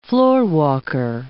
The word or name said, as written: floorwalker